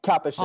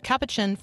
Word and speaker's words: Capuchin: Capuchin. Yes? – Yeah. – Mm-hmm. Almost like cappuccino. Capuchin Franciscan Friar.